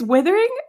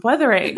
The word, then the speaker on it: Wuthering: Right before we started recording, I said to Piper, am I saying it right? It's withering? And she goes, no, that's like withering, like withering away. It's weathering.